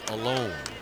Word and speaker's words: Alon: It's spelled A-L-O-N. And I asked them, I said, is this pronounced Allen or is this, how do you pronounce this? They said, it's Alone.